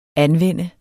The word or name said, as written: anvende